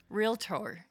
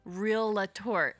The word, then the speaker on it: realtor: You said realtor. And Zach, it's okay. You're not alone. That is how 90% of the human population says that word. – So that's the correct way. – It is incorrect. – Well, how do you say it wrong? – So it's 2 syllables. Realtor. Try it. – Realtor. – Yeah. – Realtor. – Yep. That's it. Realtor. – But people put in the A.